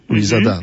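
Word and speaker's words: Luisada: Tak, Jean-Marc Louisada. Louisada, mm-hmm. Tak się akcentuje.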